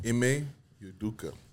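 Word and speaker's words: Ime Udoka: I know the big thing that been going on in the early of the week was how you say his name? – M.A. Udoka.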